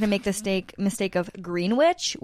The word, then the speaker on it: Greenwich: Did I say that right? – Greenwich. – I better get it right before I actually go to New York. How come there's a W in there if it's...